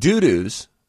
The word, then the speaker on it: Dudus: It's D-U-D-U-S. We get to choose how we pronounce it. – That's Dudus.